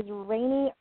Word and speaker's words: Raine: The first name is spelled R-A-I-N-E, but I know that's pronounced Rainy because she says it on her LinkedIn profile. But her last name is like Lunk, L-U-N-K-E, is Rain.